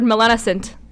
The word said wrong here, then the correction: melanocyte: Melanocyte. – Okay, apologies to all the people out there that heard me mispronounce that word. It's melanocyte.